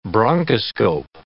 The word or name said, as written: bronchoscope